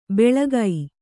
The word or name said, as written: ಬೆಳಗೈ